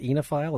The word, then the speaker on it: oenophile: It's spelled oenophile, but I understand it's pronounced enophile. Is that right? – Yes.